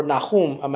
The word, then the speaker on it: Nahum: And it's spelled N-A-H-U-M. That's how they spell it. Or Nahum might be the better pronunciation in Hebrew. Nahum. And we spell that N-A-H-U-M.